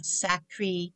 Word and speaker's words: Sancerre: Over the years, more than one sommelier has told me that when it comes to the cost of Sacré, it's spelled S-A-N-C-E-R-R-E.